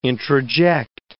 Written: introject

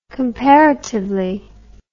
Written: comparatively

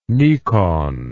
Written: Nikon

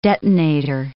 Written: detonator

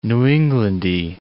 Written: New Englandy

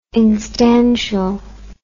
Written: instantial

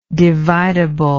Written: dividableness

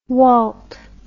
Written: Walt

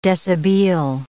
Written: deshabille

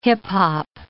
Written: hip-hop

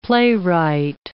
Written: playwright

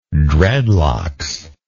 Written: dreadlocks

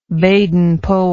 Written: baden powell

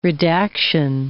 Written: redaction

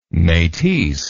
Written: metisse

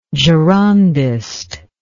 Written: Girondism

